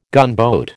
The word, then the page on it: gunboat: American English